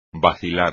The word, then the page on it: vacilar: espagnol